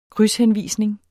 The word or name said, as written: krydshenvisning